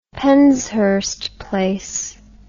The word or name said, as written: penshurst place